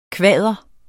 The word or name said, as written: kvader